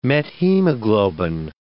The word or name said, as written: methemoglobin